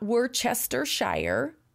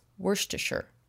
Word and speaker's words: Worcestershire: It's not Worcestershire. It's not Worcestershire. It's Worcestershire.